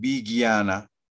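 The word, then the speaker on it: vijñāna: Este JNA es Gui ¿Sí? ¿Sí les gusta el Gui ¿Se han escuchado hablar del Gui así se pronuncia este, Guiana, Guiana. Entonces aquí es Vigiana, Ananda, el resto es normal.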